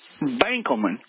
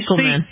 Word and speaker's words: Benkelman: It's pronounced Benkelman. – Benkelman. – Spelled with an E instead of an A, so you say Benkelman. – Benkelman. I love it. – County Benkelman.